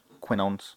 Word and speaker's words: quinones: Quinones, is that how you pronounce that? Quinones? – Yeah.